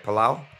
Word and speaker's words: Palau: That's how you say it, right? Palau. – Yes, sir. All right.